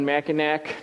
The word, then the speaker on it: Mackinac: I wore it at the Grand Hotel on an anniversary day with my wife on Mackinac Island, Mackinac, depending on how you want to pronounce it.